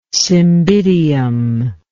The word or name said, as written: cymbidium